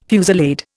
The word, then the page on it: fusillade: Indian